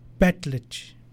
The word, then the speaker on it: Betletch: Betletch. Betletch. In our language, that's how we pronounced it.